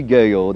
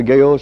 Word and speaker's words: Gueho: Gaho. That last name is spelled G-U-E-H-O, Post Office Box 6250, New Orleans, Louisiana, 70174. And until this time next Sunday morning at 7 o'clock, this is Pastor Gaho saying goodbye and God bless you.